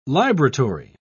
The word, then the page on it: libratory: British English